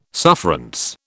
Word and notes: sufferance: British English

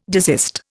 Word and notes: desist: Indian

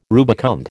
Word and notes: rubicund: American English

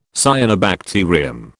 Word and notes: cyanobacterium: British English

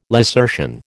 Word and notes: lacertian: American English

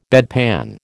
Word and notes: bedpan: American English

